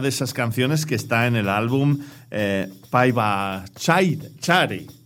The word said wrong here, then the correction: Pabaichare: Si no lo he dicho malamente. – Pabaichare, que quiere decir para bailar.